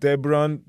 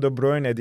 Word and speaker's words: De Bruyne: Zamanında diğer spikerlerden De Bruyne, De Bruyne, De Bruyne şeklinde telaffuz dinlerken bu arkadaş Kevin De Bruyne diye telaffuz ederek 5.